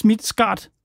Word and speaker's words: Midtskart: Johannes Midtskart, jeg håber, at du udtaler dit efternavn korrekt. Velkommen til Pit Debat. – Ja. – Var det tæt nok på? – Ja, det var okay.